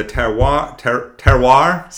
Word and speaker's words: terroir: So the terroir, see, you get one, guys, and that's it.